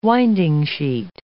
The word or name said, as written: winding-sheet